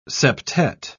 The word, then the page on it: septette: British English